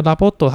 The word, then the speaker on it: LOVOT: 他们叫 Lavot，那 Lavot 它是英文 L O V O T 这样子的一个新名称，日文的发音叫 Lavot。